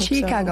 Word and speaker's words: Chiikago: I hope Chicago. – So. – Am I saying it right? – Yes. Okay. I think people say it differently. Like, Chicago, it's fine. Both ways work.